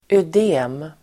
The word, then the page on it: ödem: [öd'e:m]